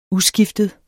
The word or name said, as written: uskiftet